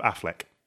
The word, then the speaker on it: Auchinleck: Auchinleck, is that how you pronounce it? – Auchinleck. – You're from Scotland. – No, of Affleck.